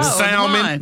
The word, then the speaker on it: salmon: Salmon. That's salmon. – Salmon has that, well, that L is supposed to be silent, ain't it? – Kind of, sort of.